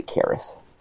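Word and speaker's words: Kharis: I usually say Karis, because it rhymes with Claris from the Evan Costello movie.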